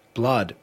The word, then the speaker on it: Blud: Blood pronounced, spelled B-L-U-D. Kind of like Young Blood spells his name.